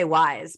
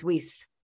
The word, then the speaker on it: Weiss: So Casey, I want to say Wise, but it's Weiss, right? – It's Weiss.